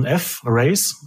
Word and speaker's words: F-Race: Und dann spricht man das halt so aus wie Fräs, also als Fräsen, aber eigentlich ist der Name F-Race.